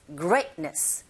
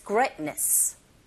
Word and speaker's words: greatness: 강세가 great, 이 부분에 있으니까 거기에 힘을 꽝 주시고 t 사운드 거의 안 들리죠. greatness, greatness. 이렇게 발음 연습하시면 됩니다. 자, Google listening 1. You grow solemn in its greatness.